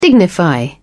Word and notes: dignify: British English

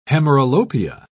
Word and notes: hemeralopia: British English

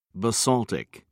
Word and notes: basaltic: British English

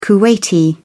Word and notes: kuwaiti: British English

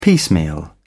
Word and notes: piecemeal: British English

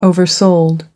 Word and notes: oversold: American English

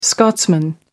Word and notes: scotsman: American English

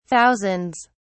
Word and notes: thousands: British English

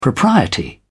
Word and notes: propriety: British English

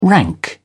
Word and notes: rank: British English

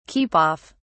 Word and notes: keep off: British English

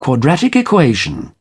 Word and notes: quadratic equation: British English

